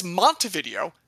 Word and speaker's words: Montevideo: Molly Schuyler is born in 1979 in Montevideo, Sorry, that's Montevideo, because it is not pronounced in Spanish, because it's in Minnesota.